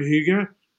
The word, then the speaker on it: hygge: I love this concept of hygge. Did I say that right? – Hygge, yes.